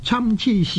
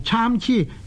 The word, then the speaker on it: 参差: 者叠韵的连绵字，例如呢参痴就是双声的连绵字，这个参痴呢就是一种不齐整的样子，在白居易的长恨歌里面有雪肤花貌参差是，参差呢亦有人读做参差，参同埋参呢都是呢双声的字，都是同一发音的，因为同一发音呢所以我们叫它做双声的连绵字，不过呢读做参呢是比较呢好一点。诗经里面有参差荇菜左右流之这一句呢亦、是呢读做参差，不是读做参差的。